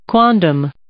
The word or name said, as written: quondam